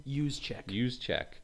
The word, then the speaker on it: Juszczyk: And Juszczyk, was that how you pronounce his name? – Juszczyk.